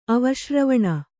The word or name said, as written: ಅವಶ್ರವಣ